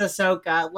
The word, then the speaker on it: Ahsoka: Did you just pronounce Ahsoka like Asuka? – It's because I don't Star Wars, and the only name that I hear that's like that is Asuka from Evangelion. Just my brain immediately I know that it's Ahsoka.